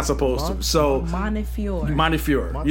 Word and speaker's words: Montefiore: How do you say it? – Montefiore. – Montefiore. – So the e, yeah. So you're not pronouncing the e. – God forgive me, because I thought it was Montefiore. – Like the e, like we're, we're we're putting we're, we're we're pronouncing that e very hard when we say it.